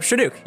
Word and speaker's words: Chinook: How do I say that? – You said it right the first time. Chinook. Yeah.